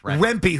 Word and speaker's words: Rempe: Yes, Rempe, who's about 8 feet tall.